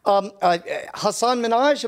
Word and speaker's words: Hasan Minhaj: Headliners include Dave Chappelle, Amy Schumer, Tina Fey, Wanda Sykes, Jerry Seinfeld, David Letterman, Chris Rock, Ali Wong, Hasan Minhaj, am I pronouncing that right? – Mm-hmm.